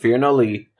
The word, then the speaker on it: Firnadli: And then I thought it was nah, N-A-H type of sounding, and then D. Phil, I'm sorry. – No, it's pronounced Fear No Lee. – Okay. – Is the discussion we had. And the way I remember it is nobody should fear us, Lees. – Oh, okay. – But it's spelled F-I-R-N-A-D-L-I in like Irish or Gaelic alphabet stuff.